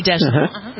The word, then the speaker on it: Deschanel: Is that how you pronounce her name? Deschanel? – Zoe yeah. Deschanel.